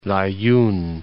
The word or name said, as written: La'youn